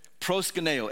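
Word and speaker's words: proskuneo (προσκυνέω): It's called, uh, it's uh, is the Greek term, is how you say it proskuneo. And it literally means, so like when we say like, oh, worship, in Greek you would say proskuneo.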